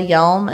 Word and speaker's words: Hjelm: which is right now at Zola Hjelm, and Hjelm is spelled H J E L M.